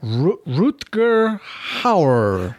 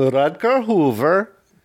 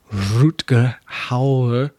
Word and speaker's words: Rutger Hauer: Or the way that he should say it if he had an accent. I am Rutger Hoover. – Sure. Yeah, because they would pronounce the U as a V.